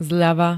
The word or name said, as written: zľava